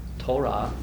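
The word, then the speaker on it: Torah (תורה): That's a T and a long O and an R and an H sound, Torah.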